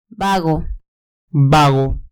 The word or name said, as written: vago